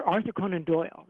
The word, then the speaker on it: Arthur Conan Doyle: He was friends with the Arthur, he was friends with Sir Conan Doyle, Sir Arthur Conan Doyle, right? That's how you say it.